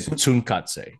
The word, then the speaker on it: Tsunkatse: I say Tsunkatse.